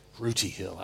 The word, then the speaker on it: Rooty Hill: Am I saying that correctly? Rooty Hill? Rooty Hill. I like that. It's rooty. Amen.